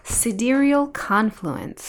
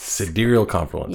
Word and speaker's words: Sidereal Confluence: Sidereal Confluence. – Sidereal Confluence.